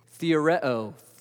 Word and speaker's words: theoreo: And the only reason I give you the Greek pronunciation of it is because it might sound familiar. What is it? Theoreo. Theory. That means he saw and the wheels started turning.